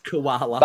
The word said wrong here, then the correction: Kuala Lumpur: But, how do you say it? – Kuala Lumpur. You're so Australian.